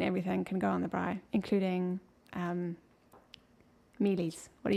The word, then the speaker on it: braai: A braai. – A braai. Which is spelled B-R-A-A-I. – That's correct.